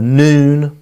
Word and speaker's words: Nun: After the death of Moses, the servant of the Lord said to Joshua, the son of Nun, or the son of Nun, probably more appropriately pronounced, Moses' assistant, Moses, my servant, is dead.